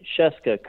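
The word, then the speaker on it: Scieszka: First off, kudos for pronouncing Sheska correctly.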